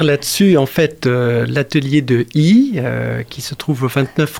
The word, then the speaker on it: Yi: Et comment ce projet, pourquoi vous utilisez le nom de Yi? – Alors, peut-être que je prononce mal d'ailleurs. – Non, très très bien. I, ça se écrit Y-I, ça se prononce I.